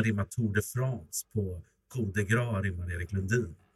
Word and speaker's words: Coup de Grâce: Det är Coup de Grâce, men det ska uttalas Coup de Grâce. Är det så? – Coup.